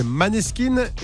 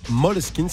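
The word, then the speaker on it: Måneskin: Alors souvent, on nous dit, mais pourquoi vous dites Moleskine Parce qu'avant, on disait Maneskin et les fans de Moleskine nous ont dit, c'est danois, ça se prononce Moleskine, ça veut dire clair de lune.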